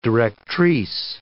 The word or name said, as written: directrice